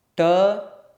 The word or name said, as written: ṭ